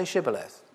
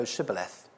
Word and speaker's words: Shibboleth: And if that person says no, they say, "Oh yeah, well, then say Shibboleth." Because it says the Ephraimites couldn't pronounce SH. And they would say, they would go Sibboleth.